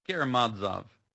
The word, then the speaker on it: Karamazov: How do you pronounce it? – Karamazov. – Karamazov. That's the correct pronunciation. Let's go with that. – The accent is almost always on the next to last syllable in these Russian names, Karamazov.